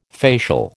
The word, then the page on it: facial: American English